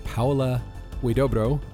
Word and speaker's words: Paula Huidobro: All right, I am at Hot Rod Cameras in Burbank, California with Paula Huidobro.